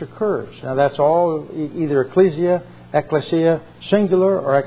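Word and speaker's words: ekklesia: How do you pronounce that word? – Ecclesia. – Ecclesia.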